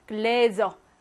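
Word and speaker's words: Gläser: 요럴 때는요, 똑같이 글라스이긴 한데, 얘는 스로 발음하고, 얘는 모음 앞에 있으니까, 글래저, 즈로 발음한다는 거.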